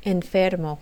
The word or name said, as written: enfermo